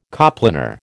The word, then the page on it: coplanar: American English